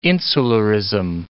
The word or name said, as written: insularism